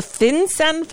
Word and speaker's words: FinCEN: The FinCEN files? Is that how it's pronounced? The what? – Yes. You've pronounced it correctly.